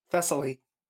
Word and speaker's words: Thessaly: We have the Sandman Universe special Thessaly? Did I pronounce that? – Thessaly, gives the ruling. – I assume, yeah. – Thessaly.